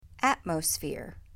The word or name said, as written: Atmosphere